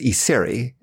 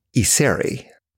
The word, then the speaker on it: Iseri: My last name is Isery. It's spelled I-S-E-R-I. – Isery. Isery? – Isery.